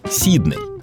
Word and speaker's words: Сидней: Традиции британской фонетики предполагают в таких случаях ударение на первый слог — Сидней. А великий и могучий на это хотел плевать, из барского плеча подарил городу аж на два ударения.